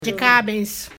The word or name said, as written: jkaabes